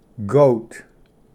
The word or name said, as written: ᎤᎦᏐᏣᏁᏓ